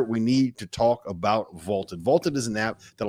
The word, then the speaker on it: VLTED: So go download the Vaulted app for free. It is spelled V-L-T-E-D.